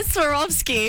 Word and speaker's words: Swarovski: Swarovski.